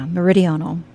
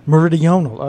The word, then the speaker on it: meridional: And I knew I was going to butcher that word. You're very close. Yeah, meridional. – Meridional.